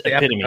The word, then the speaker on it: epitome: That's or as I've heard many people mispronounce it, the epitome. I'm like, no, it's epitome, guys.